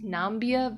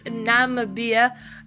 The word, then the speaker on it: Namibia: I thought it was pronounced Nambia, but I don't think so. I had to actually go and watch a video on how to pronounce this country. It's Namibia.